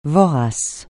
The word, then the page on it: vorace: francais